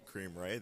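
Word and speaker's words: Kareem Rae: So if you go to Amazon and you type in Kareem Ray, there's a green book that will pop up. My name is spelled K-A-R-E-E-M, last name R-A-E, and you'll see a green book titled I Want to Become a Professional Soccer Player.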